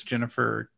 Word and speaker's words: Ginnifer: I don't know how to pronounce it, if it's Jennifer. – Yeah. Jennifer uh, Alan Tudyk though.